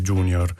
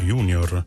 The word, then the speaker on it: junior: Scrive Vito nella pagina Facebook della Lingua Batte che da, dice lui, eredi dei romani, una parola come junior non dovremmo pronunciarla all'inglese junior, ma per l'appunto junior, alla latina e all'italiana.